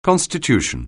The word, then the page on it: constitution: anglais